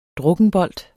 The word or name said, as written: drukkenbolt